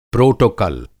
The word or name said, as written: प्रोटोकॉल